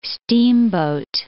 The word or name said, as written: steamboat